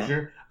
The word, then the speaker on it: Hozier: Hozier.